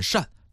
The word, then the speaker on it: 滩: 它念作滩的时候呢，就相当于是沙滩的那个滩。当然它还有一个读音念扇，比方说晚扇。晚扇就是蜿蜒的意思，水流蜿蜒曲折嘛。